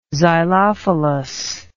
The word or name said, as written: xylophilous